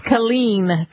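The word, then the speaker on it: Killeen: I know Fort Hood how do you pronounce the name of the town? Colleen? – It's Colleen. Colleen, Texas.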